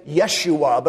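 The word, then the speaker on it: Yeshua (ישוע): There are some groups who say, well, we shouldn't say Jesus, we should say Yeshua, because that's the Hebrew pronunciation of the name Jesus.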